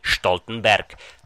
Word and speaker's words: Stoltenberg: Mimochodem, Jens Stoltenberg je germánské jméno, kde se velké S na začátku slova vyslovuje jako Š, takže foneticky Stoltenberg.